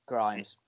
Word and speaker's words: Grimes: Is it Grimes or Grimms, or how do you pronounce that name? Again, I'm not very good with names. – Oh, the artist. – Yeah, Grimes. – Grimes.